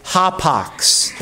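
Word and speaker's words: hapax (ἅπαξ): That once preposition in Greek is actually hapax. That's how you pronounce it in Greek.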